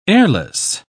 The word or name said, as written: airless